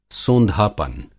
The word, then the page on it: सोंधापन: Hindi